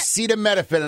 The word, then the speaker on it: acetaminophen: Acetaminophen, and I can tell you it's right already. – Effective immediately, the FDA will be notifying physicians that the use of let's see how we say that. Acetaminophen.